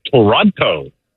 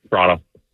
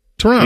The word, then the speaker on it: Toronto: Some people are guilty of saying Toronto. – Oh, come on. – Which is incorrect. Toronto. – Toronto.